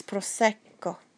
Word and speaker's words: prosecco: So when there's a double, like in prosecco, there's almost a little gap between the two C's. So it's prosecco, rosso. Any time that you see a double letter, make sure you pronounce both letters.